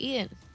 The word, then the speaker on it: Eaton: Well, that's how you say it, Eaton.